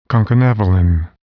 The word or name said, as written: concanavalin